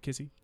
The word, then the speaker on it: Kissi: Is it Kissi? – Yeah. – I said it right? Okay. – You got it.